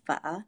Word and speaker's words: pho: Whenever I meet a hipster in Hollywood and they ask where they can find the best pho, I chuckle with dismay and my answer is always the same. It's pronounced pho, and the best place to find it is at my mom's house.